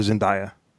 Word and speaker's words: Zendaya: I call her Zendaya.